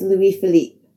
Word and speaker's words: Louis Philippe: I do know my pronunciation was completely wrong. In my defense, I just watched a movie with Ryan Philippe in it, so I guess he was on my mind a bit. I am sorry for the mispronunciation. It's Louis Philippe.